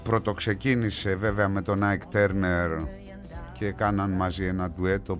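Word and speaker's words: Turner: αυτή η καταπληκτική φυσιογνωμία, Τίνα Τέρνερ ή Τάρνερ. Κάπου ανάμεσα είναι η σωστή προφορά, Τέρνερ, κάπου εκεί.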